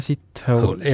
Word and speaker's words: Sithole: Tema nimi, kuula nüüd, on Jaja Sithole. – Jaja Sithole. – Jah. – Niimoodi hääldataksegi? – No, kirjutatakse S-I-T ja hole, nagu inglis keeles on see.